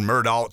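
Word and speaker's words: Murdaugh: People call it Mudaugh. You know, it's spelled Mudaugh. It's pronounced Mudaugh. Why is it Mudaugh? Because that's the way they pronounce it.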